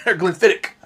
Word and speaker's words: Glenfiddich: It's spelled Glenfiddich. – Yeah. – I said that once to a... that's Scotland, though. Here, But I was talking about Scotch to a Scottish guy one time, and I said Glenfiddich, and he's like, what, mate? It's Glenfiddich. Glenfiddich. – Hmm. – It's a K, not a CH.